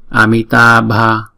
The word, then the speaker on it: Amitabha: Amitabha. Chữ B đứng một mình chúng ta đọc là B. Chữ B và chữ H ghép lại đọc là B.